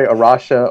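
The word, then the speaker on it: A Rocha: It's Portuguese, and it's A-R-O-C-H-A, and it means the rock. And we're in, as you said, about 20 different countries, and in each country it's pronounced differently. So no one gets it right except the Portuguese, probably. but we say Arusha or Arusha in the United States.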